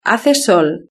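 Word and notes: hace sol: Spain

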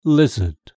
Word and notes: lizard: British English

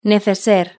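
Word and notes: neceser: Spanish (Spain)

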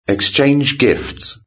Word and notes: exchange gifts: British English

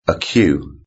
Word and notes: a queue: British English

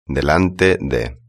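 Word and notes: delante de: Spanish (Spain)

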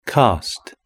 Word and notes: cast: British English

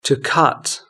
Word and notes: to cut: British English

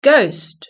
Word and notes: ghost: British English

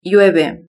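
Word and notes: llueve: Spanish (Spain)

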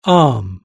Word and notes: arm: British English

